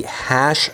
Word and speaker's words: HASSH: It's the hash library, I guess is how you pronounce it. H-A-S-S-H, and as the name sort of implies, it fingerprints SSH.